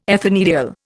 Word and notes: ethanediol: Indian

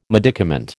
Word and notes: medicament: American English

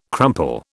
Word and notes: crumple: British English

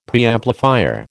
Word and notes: Preamplifier: American English

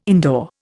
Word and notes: indoor: Indian

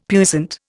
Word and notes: puissant: Indian

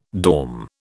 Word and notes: dorm: British English

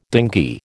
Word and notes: dinghy: American English

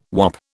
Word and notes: wop: British English

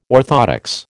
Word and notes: orthoptics: American English

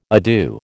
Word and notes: adieu: American English

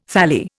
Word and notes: thalli: Indian

